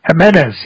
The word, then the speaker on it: Jimenez: Am I saying his name right? Jimenez, yeah.